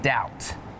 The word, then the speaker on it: doubt: And some examples with a silent B are lamb, thumb, and doubt.